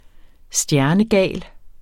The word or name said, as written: stjernegal